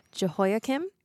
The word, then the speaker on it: Jehoiakim: Um, uh, King, I'm going to say this name wrong, Jehoiakim. – Oh, that was perfect.